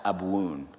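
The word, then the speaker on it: Abwoon: This is Aramaic, and the word that's circled is pronounced Abuun.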